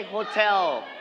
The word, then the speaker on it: hotel: Everybody say hotel. Everybody say it. Hotel.Hotel. Very good. All right. So we are going to straight on the second syllable.